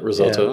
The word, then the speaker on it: risotto: How do you say it? Whatever. – Yeah. And, that's how you say it, risotto.